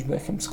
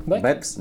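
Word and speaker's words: Beckham: Protams, ka nav viņš Beckhams. Kur tur ir e H burts? Ir? izrunā. – A, kā ir? Rakstībā. – Bekams. Be, kā? Bekams bez H.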